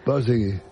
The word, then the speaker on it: Borzage: Dile cómo se dice Borzage. – Borzegui. – ¿Cómo? – Borzegui.